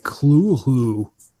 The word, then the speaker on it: Cthulhu: It says Lovecraft transcribed the pronunciation as, it looks like Clulhu. – Clulhu? – Clulhu? Clulhu. Something like that. It says the first syllable pronounced gutturally and very thickly. The U is about like that in full, and the first syllable is not unlike clull in sound. Hence, the H represents the guttural thickness.